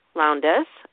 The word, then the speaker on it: Lowndes: And it stars Jessica Lowndes, and I hope I say her name correctly. Is that how you say it? – That's how I pronounce it.